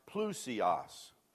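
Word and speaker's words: plousios (πλούσιος): Here, the word is that's used here is pronounced plousios. Let's say that. Plousios. You know, I hope you're saving these gems on these bulletins, man.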